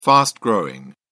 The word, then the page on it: fast-growing: British English